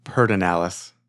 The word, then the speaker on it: Pedernales: I think a lot of people in the area says Pedernales.